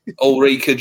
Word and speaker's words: Ulrika: Is it Eureka or Ulrika? How do you pronounce it? – Ulrika Johnson.